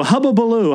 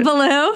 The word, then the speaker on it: hullabaloo: And then Hannah just starts doing hubba-lo-blue, hubba-baloo, hubba-baloo. – How do you say that? – Hubba-baloo. I can't say it. – Hullabaloo.